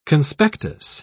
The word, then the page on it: conspectus: British English